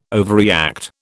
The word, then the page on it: overreact: British English